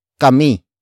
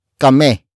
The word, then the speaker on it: kami: Kami is spelled as K-A-M-I. It means we, except you in focus form. Some Tagalog speakers would relax the last I in kami so it becomes kame. Kame. Kame. Nevertheless, it is still spelled as K-A-M-I.